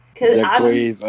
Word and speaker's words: Jacquees: Jacquees? – Jacquees, I don't think anybody should be king of anything.